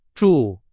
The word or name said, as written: 住